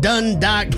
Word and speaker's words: Dundalk: We got Jamie on the line representing out of Dundalk, Maryland this morning. – I don't know why I laugh every time you say the city. – Am I saying it properly? – You are saying it exactly right.